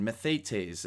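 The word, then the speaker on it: mathetes (μαθητής): And again, mathetes is how you pronounce it in Greek, and it involves both learning and relationship.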